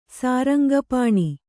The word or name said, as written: ಸಾರಂಗಪಾಣಿ